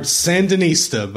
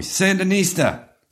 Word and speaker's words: Sandinista: We're going to regard Sandinista by The Clash. – You said it properly. Sandinista. – It has an exclamation mark at the end. – You have to say Sandinista.